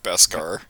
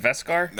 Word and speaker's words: Beskar: because it showed that if you're wearing your Gespar, am I saying that right? – Beskar. – Beskar, yeah. Or say it again. – Isn't it Veskar? – No, it's B. Like B as a boy.